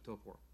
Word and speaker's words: Taupo: There's a beautiful lake in the middle of the North Island of New Zealand called Lake Taupo, Lake Taupo, but pronounced Lake Taupo.